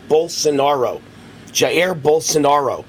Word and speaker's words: Jair Bolsonaro: Bolsonaro, excuse me, Bolsonaro Jair Bolsonaro.